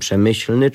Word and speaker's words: Przemyśl: Słowo to w oryginale brzmi jak półtora kichnięcia, powiedzmy jak drive z półwolejem, coś w rodzaju Przemyśl.